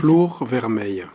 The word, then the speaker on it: Flore Vermeil: Flore Vermeil, comment vous le prononcez ? – Flore Vermeil. – Ah, c'est du Provençal.